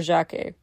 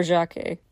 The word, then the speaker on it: Jacques: It was Frere Jacques. Actually, Jacques, or Jacques, as in some versions for the syllable count's sake it's pronounced, it doesn't translate to John.